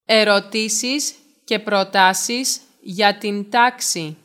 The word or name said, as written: Ερωτήσεις και προτάσεις